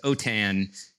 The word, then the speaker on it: OTAN: And little side note, if you're from French or Spanish, then it's OTAN is how the acronym is pronounced.